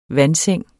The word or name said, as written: vandseng